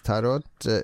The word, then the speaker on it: tarot: Men på norsk ordboka tarot uttaler tarot. – Tarot. Tarot. Med sånn fransk R.